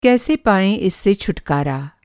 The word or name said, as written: छुटकारा